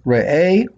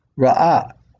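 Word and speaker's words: re'eh (ראה): The word in Hebrew is re'eh. It can be pronounced re'eh or ra'ah.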